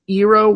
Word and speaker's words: Eero: There's the, I guess, Eero Wi-Fi, if that's how you pronounce it. It's E-E-R-O.